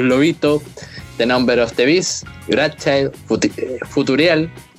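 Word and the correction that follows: Futureal: ¿Cómo se pronuncia esa canción, weón? Future Real. Sí, es como Future Cereal.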